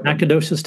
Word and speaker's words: Nacogdoches: Austin State University in Nacogdoches, Texas. Am I pronouncing that right, Matt? – Yes, you are. Yep. – Nacogdoches, Texas.